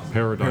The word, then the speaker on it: paradigm: That's so important to say, but it's pronounced paradigm. – Paradigm. – Huh. This has been wrong in my vocabulary for the past 25 years.